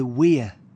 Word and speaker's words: Wear: No, this is the Weir. – The Weir? How do you spell it? – W-E-A-R. – W-E-A-R. Like wear, the verb. – I guess so, yeah. Spelt the same as wear, but pronounced weir.